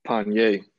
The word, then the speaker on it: Panier: Nah, Panier. It's kind of like Kanye with a P.